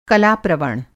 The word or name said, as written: कला-प्रवण